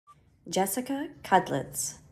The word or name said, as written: Jessica Kudlats